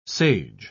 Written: sage

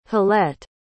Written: halette

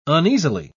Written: uneasily

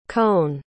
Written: cohn

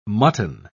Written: mutton

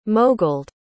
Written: moguled

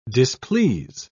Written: displease

